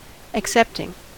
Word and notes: accepting: American English

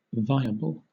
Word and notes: viable: British English

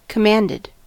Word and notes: commanded: American English

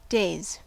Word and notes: days: American English

